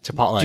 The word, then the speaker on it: chipotle: Chipotle. – Do you pronounce chipotle? – It's chipotle.